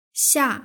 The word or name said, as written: xia